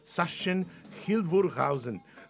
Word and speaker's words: Sachsen-Hildburghausen: Pronunciación germánica. Sachsen-Hildburghausen.